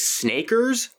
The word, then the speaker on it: SNKRX: I have been playing a game that someone recommended to me on Twitter called Snakers. – Snakers. – It's spelled SNKRX.